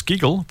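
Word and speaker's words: gigil: G-O-G-G-O. So giggling as in laughter, yes, so it's not that. – It's G-I-G-I-L, but it's still pronounced giggle as far as I can work out.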